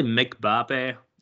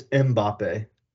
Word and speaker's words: Mbappe: We saw great players, you know, Messi and Mbappe really step up and take control of the game. And it was amazing. – So I say, just quick clarification, I say Mbappe. Is it Mbappe? – It's Mbappe.